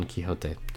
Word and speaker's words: Quixote: Rosinante Don Quijito. Quixote. I said that wrong. Don Quixote.